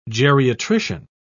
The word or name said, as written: geriatrician